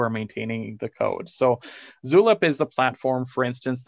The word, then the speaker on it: Zulip: What other, so you mentioned River, which again, this is R-Y-V-E-R, not River. We talked about something called Zulip. Am I saying it correctly? Zulip. – Like, yeah, Zulip. – Tulip with a Z. – Tulip with a Z, yeah.